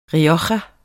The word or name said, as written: rioja